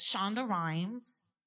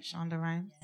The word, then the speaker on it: Shonda Rhimes: It's Shonda Rhimes. I said it right? Shonda Rhimes.